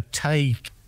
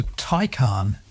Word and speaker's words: Taycan: I might have said a Tay, but it's not that, is it? – No, it's a Taycan. – Well, T-A-Y-N.